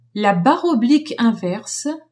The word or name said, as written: barre oblique inverse